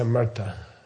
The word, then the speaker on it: Amrita: And everyone pronounces it wrong, even like yoga teachers from India. Okay? It's Amrita.